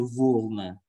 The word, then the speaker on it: волна: Это не волна, это волна, волна белая волна. Белая волна, ударение на первый слог, это есть белая шерсть.